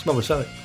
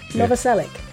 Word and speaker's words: Novoselic: I've never known how to pronounce his name. Any more takes? – I know. It's Novoselic. – Novoselic.